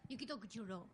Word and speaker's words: Yukito Kishiro: Yukito Kishiro.